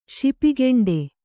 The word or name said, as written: ಶಿಪಿಗೆಂಡೆ